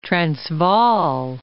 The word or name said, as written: Transvaal